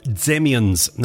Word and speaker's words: Dzemians: It's some new music as well from the Zemians. Now, it's spelled D-Z-E-M-I-A-N-S.